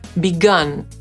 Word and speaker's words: begun: No i tu będzie właśnie taki przykład przy okazji z użyciem trzeciej formy czasownika, czyli begun. Piszemy to przez U, a wymawiamy tę końcówkę tak jak broń. Gun.